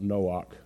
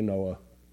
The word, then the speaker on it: Noah: Don't go around talking about Noach. Nobody will know who you're talking about. That's ridiculous. Just say Noah.